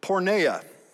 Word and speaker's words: porneia: Well, the word that Paul uses here is one that's used frequently in the New Testament, and it's the word porneia. It's a very broad term, but something might sound familiar, especially that first syllable, porn.